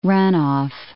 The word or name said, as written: runoff